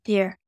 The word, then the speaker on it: DIER: The DIER, do you pronounce that DIER or is it just DIER? – I pronounce it as DIER.